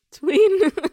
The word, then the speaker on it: Tweeden: Tweeden rhymes with Sweden, I think.